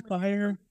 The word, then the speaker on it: FHIR: In the FHIR community, because FHIR is pronounced FHIR. There's all these puns about things being on FHIR,